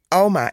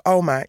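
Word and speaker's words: ouma: En, en, en zij heette niet eh, oma, maar Alma. Alma is de uitspraak. – Alma, ja, O-U.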